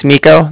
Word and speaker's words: Niko: Okay, so my name's Nico. That's spelled N-I-K-O. And then the Bank of Cardiff is spelled C-A-R-D-I-F-F, as in Frank, Frank.